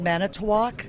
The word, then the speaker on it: Manitowoc: Sue Vliet and a few more of you shook your fingers at us because yesterday we mispronounced the name of that Wisconsin city. So our correspondent, Don Gagne, paid a morning visit to a diner there, Warren's Restaurant, for some help. – The city is Manitowoc. – Manitowoc. – M-A-N-I-T-O-W-O-C.